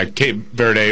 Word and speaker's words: Cape Verde: For the old guys everywhere and for Cape Verde, or is that how you pronounce that, by the way? – Yeah, well, I think that right. – Yeah, Cape Verde, or in Portuguese, it's Cabo Verde.